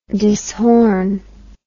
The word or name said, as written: dishorn